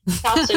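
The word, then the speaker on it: katso: perché poi ho scoperto che questa parola, che si pronuncia esattamente come in italiano, cazzo in finlandese vuol dire guarda, quindi in realtà è più un gioco di parole, guarda, tu guardi, invece che la solita frase, diciamo, irriverente.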